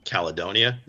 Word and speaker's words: Caledonia: You mentioned the community aspect of things in Caledonia. Is that how you say it? – Yeah.